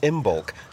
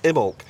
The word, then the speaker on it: Imbolc: We've done another corner because we thought it was pronounced Imbolc. This week we discovered it's pronounced Imolc.